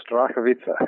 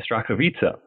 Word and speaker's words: Starachowice: Strakovice, is that correct? – Very close, Strakovice. – Okay, Strakovice.